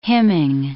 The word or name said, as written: hymning